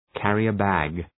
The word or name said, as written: carrier-bag